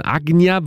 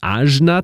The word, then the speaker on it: Ajna: Ajna wird es eigentlich im Sanskrit ausgesprochen. Die meisten würden wahrscheinlich Ajna zu sagen. A-J-N-A geschrieben, ist aber gleichzeitig auch ein belgischer Produzent. A-J-N-A geschrieben, der sich hier mit Mischa zusammengetan hat.